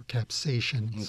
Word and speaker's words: capsaicin: The pronunciation varies. Capsicum is the name of the compound, or capsaicin, sometimes pronounced.